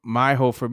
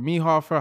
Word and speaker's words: Meihofer: Meihofer, Meihofer, Hoff, yeah.